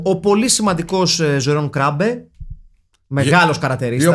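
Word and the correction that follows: Jeroen Krabbé: Ή όπω λένε οι Ολλανδοί, όπω λένε στο Άντρε, Γερόν Κραμπέ. – Έτσι είναι η προφορά.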